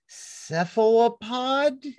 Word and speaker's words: cephalopods: I think this is a word we recently had on the podcast, and I still am too ignorant to pronounce it. Cephalopods. – That's exactly right.